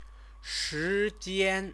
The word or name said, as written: shijian